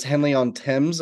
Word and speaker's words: Henley-on-Thames: And that is that you grew up right next to, I guess, Henley-on-Thames.